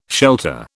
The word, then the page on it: Shelta: British English